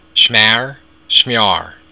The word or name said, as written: smear